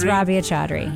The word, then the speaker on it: Rabia Chaudhry: Rabia Chaudhry? – It's Rabia Chaudhry, yes.